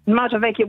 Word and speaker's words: Motovecchia: Is that how you say it? – Yes, it is. Motovecchia, which means old motorbike in Italian.